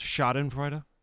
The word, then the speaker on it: Schadenfreude: I believe it's pronounced Schadenfreude. – What did I say? – Schadenfreude. – Yeah. Peter Schadenfreude? Yeah. Schadenfreude. – Schadenfreude. – I believe it's a German word.